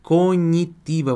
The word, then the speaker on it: cognitiva: Cognitiva. Você vai ter que ler ela como se ela tivesse escrito assim, ó. Como conhaque.